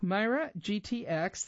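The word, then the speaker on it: MeiraGTx: Myra GTX, that's spelled M-E-I-R-A, capital G-T-X, manufactured its LCA4 gene therapy under a special license from the Medicines and Healthcare Products Regulatory Agency, MHRA, in the UK.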